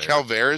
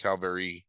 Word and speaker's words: Calvaire: But the movie is Calvaire, which I looked up. That is, it's Calvaire. – Calvaire is how you say it? – Yep. – I swear I heard somebody say it Calvary. Like some Frenchman.